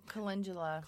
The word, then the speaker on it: calendula: You can also make a poultice of calendula. Is that, I had never pronounced that right. – I know, calendula.